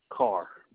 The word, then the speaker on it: Carr: Carol Carr. – Spell that, is that K-A-R-R? – Nope. Our last name is C-A-R-R.